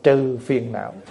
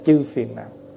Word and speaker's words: chư phiền não: Và sau này mình đọc riết nó trải thành trừ phiền não, chứ đọc đúng chữ là chư phiền não.